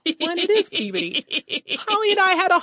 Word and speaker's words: Peabody: I could be mistaken since it is the museum name, but the town of Peabody is pronounced Peabody.